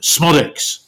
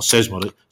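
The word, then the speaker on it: Szmodics: Yeah, he seems a bit like a, I'll get the pronunciation right, Smodics. Not Sesmodic, Smodic, sort of like mercurial, talented.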